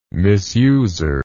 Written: misuser

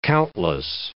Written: countless

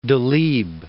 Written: Delibes

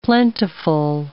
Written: plentiful

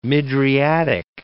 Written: mydriatic

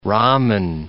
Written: ramen

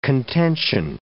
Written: contention

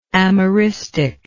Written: amerism

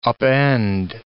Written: upend

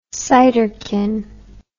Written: ciderkin

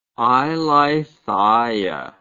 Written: eileithyia

